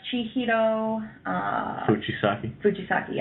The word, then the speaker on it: Chihiro Fujisaki: Oh, that's Chihiro... – Fujisaki, yeah.